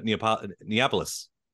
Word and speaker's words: Neapolis: I was listening to the Neapolis. Did I say that right? That album. – Yeah, you did. You did. Yeah.